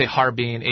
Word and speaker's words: Harbin: And in English, we say Harbin, H-A-R-B-I-N. In Chinese, you say Ha-Arbin. It's got three syllables instead.